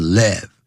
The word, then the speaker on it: live: ？Of live 这边当形容词用，不要念成 live，代表现场的 orchestra 管弦乐团啊，他们这个当时的管弦乐团，那就是在现场呢，这个演奏音乐，随着这个音乐跳舞。